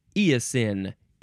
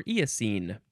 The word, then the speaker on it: eosin: Here we go with eosin. E-O-S-I-N. Eosin. You can also spell it with an E at the end, and it can be pronounced eosin also or eosine.